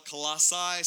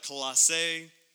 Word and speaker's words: Colossae: And so some people call it Colossae, some people call it Colossia, I call it Colossae, tomato, tomato, whatever you want to call it is fine.